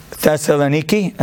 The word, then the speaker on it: Thessaloniki: There was a town in Greece called Thessaloniki, if I'm pronouncing it right. Thessaloniki, that's what it is.